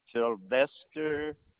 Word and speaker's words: Sylvester: Sylvester Green.